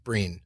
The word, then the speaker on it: Brian: It's pronounced, spelled like Brian, but pronounced Breen.